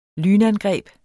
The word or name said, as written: lynangreb